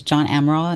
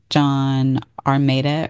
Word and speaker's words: Amaral: One of them was John Amaral, and I said his name correctly at the beginning. And then towards the ending, I reference him as well, and I say his name incorrectly. I think I say something like John Armada or something like that.